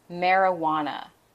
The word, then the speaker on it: marijuana: Pronunciation is marijuana. Marijuana. Marijuana, that JU combination is pronounced like a W.